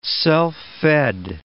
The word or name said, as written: self-fed